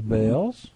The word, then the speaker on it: Baal: Which was Baal's. I guess that's how you say it in English, Baal, right? – Mm-hmm.